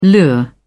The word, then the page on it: lure: British English